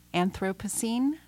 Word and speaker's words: Anthropocene: That track was Burden of Proof off of your latest album, Anthropocene.